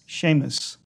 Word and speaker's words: Seamus: Seamus. – So where does that come from? Why is this also in this S sound making a shh?